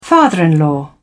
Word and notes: father-in-law: British English

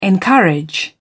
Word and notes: encourage: British English

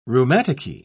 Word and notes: rheumaticky: British English

